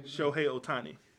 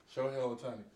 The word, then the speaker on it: Shohei Ohtani: Shohei Ohtani. – Shohei Ohtani.